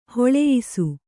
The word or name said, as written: ಹೊಲೆಯಿಸು